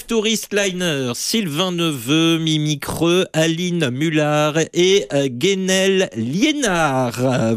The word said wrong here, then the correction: Guénaël Liénard: Voilà, donc Guénaël Liénard, pardon, pour j'ai écorché un petit peu.